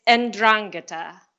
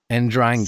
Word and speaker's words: 'Ndrangheta: It's 'Ndrangheta. – 'Ndrangheta.